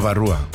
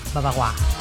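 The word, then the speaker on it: Bavarois: Babarúa. – Babarúa. – Uh-huh. Babajúa sería. – Se escribe Babarois. – No sé, sí, pero sería Babarúa. – Babagua.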